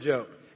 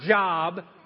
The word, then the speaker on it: Job: And you can fill that in if you want to, the story of Job. It's not Job, although it's spelled the same way, but it's actually the word Job, and it's his story.